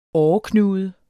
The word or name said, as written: åreknude